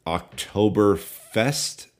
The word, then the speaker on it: Oktoberfest: The first being that I believe it's called, Dan, Oktoberfest. Is it? Did I get that right? Or what's happening there? – Yeah, typically we butcher names and pronunciations on this show, but it's just A-plus effort there.